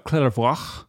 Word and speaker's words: Clairvaux: Of Clairvaux.